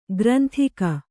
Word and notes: ಗ್ರಂದಿಗೆ: Kannada

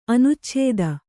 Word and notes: ಅನುಚ್ಛೇದ: anucchēda

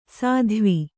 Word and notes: ಸಾಧ್ವಿ: sādhvi

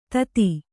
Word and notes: ಥತ್: Kannada

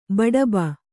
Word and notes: ಬಡಬ: baḍaba

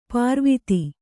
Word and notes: ಪಾರ್ವಿತಿ: pārviti

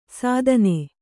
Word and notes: ಸಾಧನೆ: sādhane

